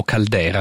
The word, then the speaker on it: Calder: Beh, sono quegli equilibri momentanei ed effimeri che troviamo anche nelle opere di Alexander Calder, o Caldera, a seconda della pronuncia, è famoso per i suoi mobiles, queste sculture eteree, sospese.